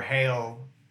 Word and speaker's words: Hale: Halle. H-A-L-E, Hale. – H-A-L-E, Hale.